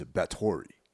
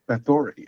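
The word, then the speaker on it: Báthory: And the way they pronounce it is Bathory. – Bathory, yes.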